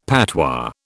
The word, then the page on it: patois: British English